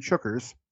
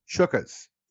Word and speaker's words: chookas: For future reference and sidebar, guys, our obligatory cheers and chookers toasts. It's pronounced chookas, as in chook.